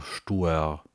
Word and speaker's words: Stuer: Stür. Also eine Gemeinde im Landkreis Mecklenburgische Seenplatte in Mecklenburg-Vorpommern. Stür, S-T-U-E-R. So wird das, glaube ich, ausgesprochen.